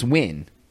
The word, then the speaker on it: Nguyen: It's like N-G-U-Y-E-N is win. That's how it's pronounced.